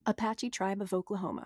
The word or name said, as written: Apache